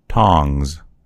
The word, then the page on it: tongs: American English